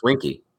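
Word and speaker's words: Weinke: It's not it's not like W-E-N. – It is W-E-N. – No, it's spelled W-E-I. – Right. – But it's pronounced Winky.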